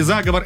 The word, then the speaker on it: заговор: Да, вы сказали заговор, это правильно.